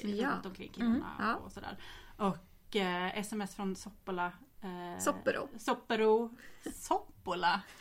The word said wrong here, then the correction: Coppola: Alltså Coppola.